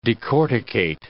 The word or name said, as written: decorticate